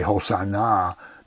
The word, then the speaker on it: Hosanna: And if you say Hosanna, that means come and save us now. It puts the emphasis on the last syllable, means come and save us now.